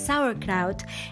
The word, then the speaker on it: sauerkraut: it's pronounced sauerkraut, and I now added a new link on the description about how to make it and how long it takes.